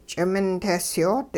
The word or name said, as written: ch'imintehsyoot